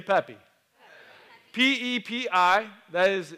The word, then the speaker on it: Pepi: Pepi in ancient Egyptian is pronounced Charles. No, it's just Pepi.